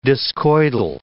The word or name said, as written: discoidal